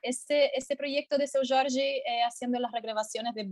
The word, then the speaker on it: Seu Jorge: ¿y qué te pasa a ti con cosas como, como cuando Seu Jorge, ¿cómo se pronuncia? Seu, Seu Jorge.